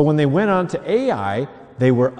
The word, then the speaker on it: Ai: Now, let's say that city by the name is named Ai.